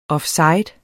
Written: offside

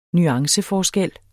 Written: nuanceforskel

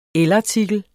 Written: elartikel

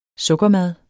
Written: sukkermad

